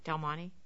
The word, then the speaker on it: Del Monte: Did I say that right? Del Monte? – Yes, I believe so. Okay. That's how I've been saying it.